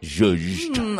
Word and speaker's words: Zhuzh: Check out Get Zhuzhed. .com. That's spelled GETJUJED. Get Zhuzhed. – I love that. Zhuzh. – So it's Zhuzh. Yes.